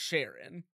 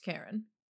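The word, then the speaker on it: Charon: I the ferryman on the River Styx is pronounced Karen.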